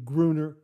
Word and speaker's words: Gruner: But Gary, thanks very much for confirming that I was pronouncing the name Gruner correctly.